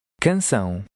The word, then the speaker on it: canção: In number 8, remember that an accent indicates not only pronunciation, but also word stress. Try to read number 8. – Canção.